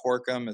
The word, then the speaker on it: Torcom: My last name is spelled Torkum.